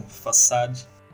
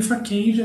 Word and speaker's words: façade: É, eu queria aqui um façade. Então, eu chamava de faquenge até descobri que eu tava falando errado.